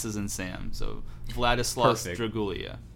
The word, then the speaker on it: Vladislaus: It's Vladislaus Dragulia. It's not Vladius, it's Vladislaus.